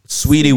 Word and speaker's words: Saweetie: Saweetie with an A in the middle.